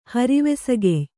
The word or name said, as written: ಹರಿವೆಸಗೆಯ್